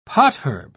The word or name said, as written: potherb